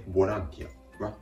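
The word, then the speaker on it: volunteer: 英語でボランティアはボランティア。 1 点だけ発音のポイント、アクセントの位置ですね。日本語ではボランティアとなるんですけれども、英語の単語ではボランティア、ボランティアと語末にアクセントがあることに注意です。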